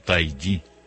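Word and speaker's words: Taigi: Es muy hermosa la vida de los santos y mala de Ana María Taiji, o Taiji, como se lleva el acento en la I.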